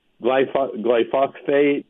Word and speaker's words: glyphosate: No, you pronounce it properly. It is glyphosate. I mean, some people say glyphosate, glyphosate. Those are all wrong. Glyphosate is the correct pronunciation.